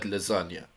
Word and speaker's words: lasagna: No, the real simile is supposed to be that the G is silent in the word lasagna, right?